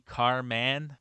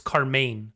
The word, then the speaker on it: Carman: Maybe he did intend it to be Carman. – We've been saying it wrong this whole time. It's Carmain.